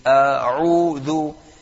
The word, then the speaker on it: أعوذ: A'udhu, so it starts off with a hamza, and the immediate second letter is an ayn. So therefore the correct pronunciation would be أعوذ بالله من الشيطان الرجيم. – So there's a hamza first, and then the ayn, which is أعوذ. So it wouldn't be أعوذ. أعو is hamza and a hamza.